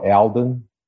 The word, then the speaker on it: Alden: Oh, Alden. You know, Alden? I do a lot of, Alden. You know, I hear like Alvin.